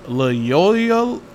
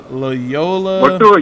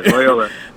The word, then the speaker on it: Loyola: but Loyola? Wait, I'm totally screwing that up. Loyola. – You got Paramount. – It. Yeah, Loyola.